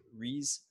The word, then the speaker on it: Rhys: Rhys.